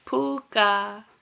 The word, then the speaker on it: pooka: So, the meaning of puka, which is, it's spelled P-O-O-K-A, and it is pronounced exactly like it's spelled, is puka.